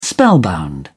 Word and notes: spellbound: British English

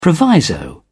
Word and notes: proviso: British English

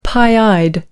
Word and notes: pie-eyed: American English

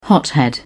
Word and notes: hothead: British English

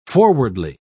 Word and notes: forwardly: British English

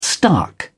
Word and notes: stark: British English

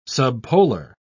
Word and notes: subpolar: British English

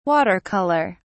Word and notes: water color: British English

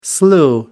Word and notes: slew: American English

